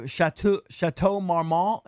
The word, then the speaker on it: Chateau Marmont: Remember, she was recently at the Chateau Marmont. Is that how you say that? – That is how you say it, yes.